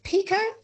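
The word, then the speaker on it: Pekoe: Maybe like, what is it, orange Pico? Pico? Is that how you say it? – Yeah.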